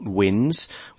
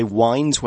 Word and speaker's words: winds: Be careful to pronounce that word winds. It's not winds. We say winds when it kind of follows a sort of twisting, turning track.